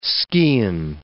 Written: skene

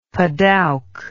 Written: padauk

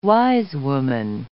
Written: wisewoman

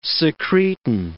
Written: secretin

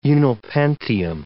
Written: unnilpentium

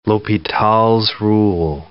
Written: L'Hopital's rule

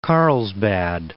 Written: Carlsbad

